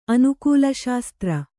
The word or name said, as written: ಅನುಕೂಲಶಾಸ್ತ್ರ